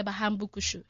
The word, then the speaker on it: Bahambukushu: It's a culture of people, the Bahero and the Bahambukushu. – Hambukushu. – Bahambukushu. – Bahambukushu. Are you from... Put emphasis on the kushu.